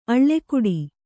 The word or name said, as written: ಅಳ್ಕಾಡು